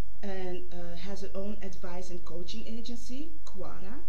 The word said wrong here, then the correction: Kara: Am I saying it right? – No, actually it's Kara.